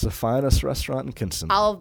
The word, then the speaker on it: Kinston: I mean, I remember when we went to Kinston for his decision.